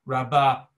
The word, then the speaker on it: rabba: So usually rabba is in the has the accent on the second syllable.